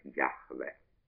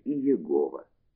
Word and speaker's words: Яхве: и произносившееся «Яхве». Уже очень рано это имя стало считаться величайшей святыней, и потому его точное произношение сделалось табу и было забыто, а «Я-Ха-В-Ха» превратилось в идеограмму, произносимую постоянно как «Адонай», «Господь, мой господин». Вследствие этого гласные звуки слова «Адонай» как бы слились с согласными тетраграмматона. Отсюда традиционное его произношение «Егова» или «Иегова».